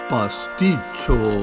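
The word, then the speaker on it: pasticcio: Pasticho. Pasticho, jóvenes, se escribe pasticio, con dos Cs, y viene de la palabra pastizio, que era un pastel de carne y pasta, como un timbal de pasta en Grecia.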